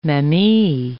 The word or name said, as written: mamey